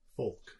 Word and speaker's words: folk: Folk. – So, there is an L in there, man. – No, it's a silent L. – It's not silent. – It's definitely silent.